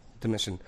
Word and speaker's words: Domitian: Domitian.